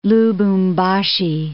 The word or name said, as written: Lubumbashi